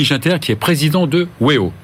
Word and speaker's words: Weo: Ça se prononce comme ça en américain. Weo, Weo en américain. Ah, Weo. Et Weo en français.